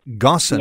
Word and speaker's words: Ghosn: Margaret Gossan. – Thank you. – And Margaret's surname is spelled G-H-O-S-N. Gossan, yep. – G-H-O-S-N.